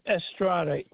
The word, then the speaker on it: Estrada: The first name is Frank, F-R-A-N-K. – Okay. Last name? – Last name is Estrada, E-S-T-R-A-D-A.